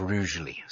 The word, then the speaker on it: Rugeley: And then the first night we were staying in Stoke-on-Trent at a B&B, and our host put us straight and said it's pronounced Rugeley.